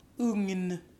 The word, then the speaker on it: ugn: So GN, you actually pronounce the first thing as an NG and then another N. – Yes, like oven. Ugn.